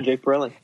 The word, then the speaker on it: Borelli: Borelli. Am I pronouncing it right? – Yeah, Jake Borelli.